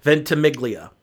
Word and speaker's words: Ventimiglia: And then the main lead is a guy named Milo Ventimiglia. I want to say it's Italian. Ventimiglia. Unless he doesn't want us to pronounce it the Italian way, which is Ventimiglia.